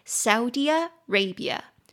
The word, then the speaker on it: Saudi Arabia: 念作 Saudi Arabia. Saudi Arabia. Saudi Arabia. 六个音节 Sau, S-A-U, D-D-I, A, Ray, R-A, B-B-I, R-A, Saudi Arabia, 所以中音节在第一音节 Saudi Arabia, 第二音节在 Ray 那边了。